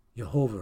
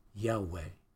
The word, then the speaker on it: Yahweh: In the Old Testament, in the Bible, the word for Jehovah, or, which is more accurately pronounced Yahweh.